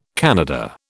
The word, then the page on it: Kannada: British English